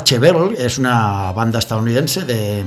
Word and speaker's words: Chevelle: Chevel se llama, Chevelle se escribe, ¿vale?